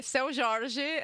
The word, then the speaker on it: Seu Jorge: We're going to be putting on by Seo Jorge, Mina do Condominio. Did I say that correctly, or did I butcher it? Close enough. It's a song by Seu Jorge, and the song is called Mina do Condominio, which translates to That Girl from My Condo.